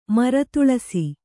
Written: ಮರತುಳಸಿ

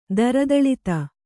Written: ದರದಳಿತ

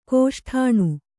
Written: ಕೋಷ್ಠಾಣು

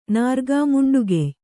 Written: ನಾರ್ಗಾಮುಂಡುಗೆಯ್

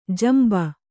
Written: ಜಂಬಿ

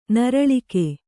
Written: ನೀರಳ್ಕೆ

